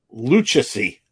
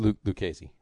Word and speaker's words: Lucchesi: Lucchesi. Joey, I believe it's pronounced. – Lucchesi.